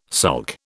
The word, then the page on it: sulk: British English